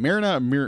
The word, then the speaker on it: Mariner: So that, to me, was kind of reminiscent of that for Marina. I was going to say it wrong. Mariner. Mariner. Thank you.